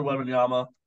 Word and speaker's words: Wembanyama: Victor Wembanyama. Did I even pronounce that right? I don't know. Um, you got this. Have more confidence. Yes, that is how you pronounce his name.